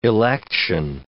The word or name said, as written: election